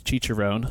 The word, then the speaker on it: Cicerone: It's pronounced Cicerone.